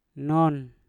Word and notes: non: Mpur

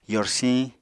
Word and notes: yorsiñ: apatani

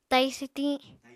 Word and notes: tai swtiñ: apatani

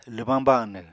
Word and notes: lwbáñ banw: Apatani

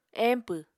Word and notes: empw: Apatani

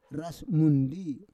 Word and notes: ras mundi: Roon

